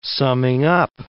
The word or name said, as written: summing-up